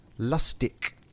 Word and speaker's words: Lustig: Well, it turns out on the pronunciation, in proper German, apparently you'd say Lustig, like with a K. You'd pronounce it Lustig.